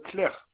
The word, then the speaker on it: Claire: Claire et Geoffrey. – Le nom s'écrit comment? – Claire, C-L-A-I-R-E, comme le prénom Claire.